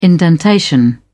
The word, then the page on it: indentation: British English